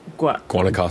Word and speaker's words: Guanacaste: How do you say it? – Guanacaste. – Guanacaste? Yeah, – That's how I would say it.